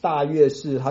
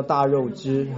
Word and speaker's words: 大月氏: 我不晓得，就是那个时候记载的一些地名很奇怪啊，他不照正常的发音哈，可能是当时的发音全部都是破音字，他不叫大碗，他叫大渊啊，他不叫那个大月氏，他叫大肉汁哈，然后不叫那个深读叫捐读哈，印度叫捐读。